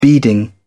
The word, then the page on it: beading: British English